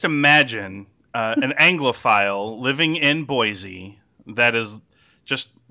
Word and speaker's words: Boise: But this is somebody in, let me see, hang on, in Boise in Idaho. – Boise. – Oh, Boise. – Yeah. It's a shame. The way I pronounced it sounded more fun.